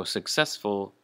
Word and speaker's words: successful: So successful, the emphasis is on the second syllable, success, or ses, successful.